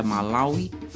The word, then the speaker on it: Malawi: Malawi, palavra aportuguesada para Malawi, Malawi, Malawi e ainda Malawi, que é a pronúncia mais próxima da língua Sinianja, à qual aquela palavra pertence.